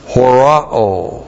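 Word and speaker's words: Horao (ὁράω): This is the aorist active imperative of the verb horao. H-O-R-A long O. Horao.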